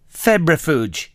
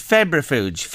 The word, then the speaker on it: febrifuge: F-E-B. – Yeah. – Or I. – Yeah. – F-U-G-E. – Febrifuge. – Yes, it's medication to reduce a fever. – Is it febrifuge? Febrifuge it is.